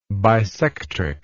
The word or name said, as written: bisectrix